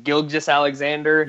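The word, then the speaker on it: Gilgeous-Alexander: Shea, I don't even know how to say his last name. Gilgeous Alexander. – Yep.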